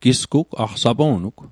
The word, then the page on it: aq: Mi'gmaq